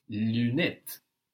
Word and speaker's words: lunettes: And of course, the UN in Lunettes. – is not one syllable like in lundi, no? Lunette.